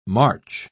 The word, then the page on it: march: British English